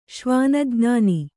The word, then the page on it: ಶ್ವಾನಜ್ಞಾನಿ: śvāna jñāni